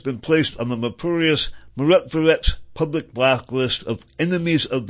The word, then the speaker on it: Myrotvorets: For his part, Shary has been on the notorious Mirot Verets, one more time, that's spelled, by the way, M-Y-R-O-T-V-O-R-E-T-S, and those are Anton Gerashenko and Mirot Verets, or prop or not.